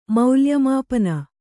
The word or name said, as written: ಮೌಲ್ಯಮಾಪನ